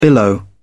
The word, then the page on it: billow: British English